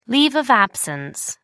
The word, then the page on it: leave of absence: American English